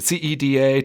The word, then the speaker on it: CEDA: But tell us about CEDA. Am I pronouncing it? Is it C E D A? Tell me a little bit about where you're working and what you're doing right now. – Yeah, it's CEDA, the uh, Community Economic Development Association in Cook County.